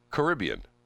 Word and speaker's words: Caribbean: C-A-R-I-B-B-E-A-N. – Caribbean.